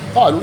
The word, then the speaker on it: قالوا: وتكتب الألف من قبيل مثلا قالوا، بعد واو الجماعة قالوا، قالوا يعني تكتب ولا تنطق، قالوا قالوا بس الواو أنا أذكرها آخر شيء، أما الألف ما أقول قالوا قالوا ما أذكر الألف.